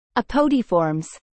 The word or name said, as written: Apodiformes